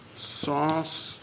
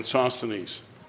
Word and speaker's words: Sosthenes: Sosthenes, I think that's how it's spelled. Is that right? S-O-S-T-H-E-N-E-S, yes.